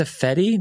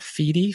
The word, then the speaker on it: Fediverse: I think it's a Fedi, not Feedy, Fediverse.